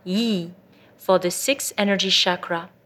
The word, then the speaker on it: Yi: The sacred mantra for the sixth energy chakra is Yi, spelled Y-I, and pronounced Yi.